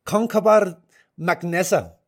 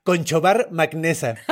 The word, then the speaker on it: Conchobar MacNessa: El rey se llama Concobar MacNessa. – Órale. – Ok, y otra vez lo voy a decir con la pronunciación celta, porque si lo lees literal es Conchobar MacNessa.